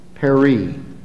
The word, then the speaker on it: peri: It's the Greek word peri. The accent on the last syllable.